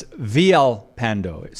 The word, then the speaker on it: Villalpando: So his name is William Villalpando, or it's Villalpando. Sometimes they pronounce the L's as Y. Let's just say Villalpando.